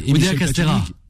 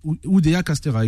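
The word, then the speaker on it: Oudéa-Castéra: Oudéa Castera. Oudéa Castera, exactement, qui ont eu lieu, je ne sais pas si c'est début du mois ou, fin du mois de janvier.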